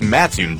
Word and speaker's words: Mattoon: Is it Mattoon or Mattoon? – It's Mattoon, dummy.